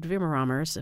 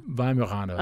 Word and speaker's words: Weimaraner: Weimaraner.